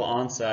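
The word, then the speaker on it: Ansah: Ezekiel Ansa, I believe is how you say it.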